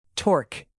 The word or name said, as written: torque